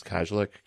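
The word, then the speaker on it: Kajlich: I always say her name wrong, it's Kajlik.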